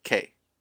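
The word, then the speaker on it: Problack: That's Problak. All right? The C is silent or invisible.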